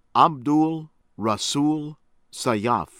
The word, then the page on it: Abdul Rasul Sayyaf: American English